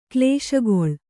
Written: ಕ್ಲೇಶಗೊಳ್

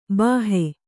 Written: ಭಾಹೊ

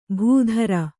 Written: ಭೂಧ್ರ

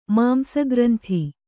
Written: ಮಾಂಸಗ್ರಂಥಿ

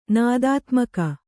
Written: ನಾದಾತ್ಮಕ

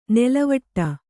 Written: ನೆಲೆವೀಡು

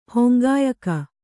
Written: ಹೊಂಗಾಯಕ

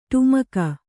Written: ಡಿಮಕಿ

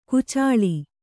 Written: ಕೊಚ್ಚಲು

